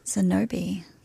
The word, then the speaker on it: Xenobe: Zenobi. – Yeah, it's spelled X-E-N-O-B-E.